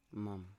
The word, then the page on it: mam: Biak